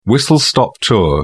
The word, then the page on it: whistle-stop tour: British English